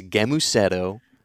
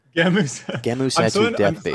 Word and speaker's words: Gemusetto: So talking about, I guess, getting back to Jem Musetto, death. Did I say it right? – First of all, it's a hard G. Okay. It's Gemusetto. – Gemusetto.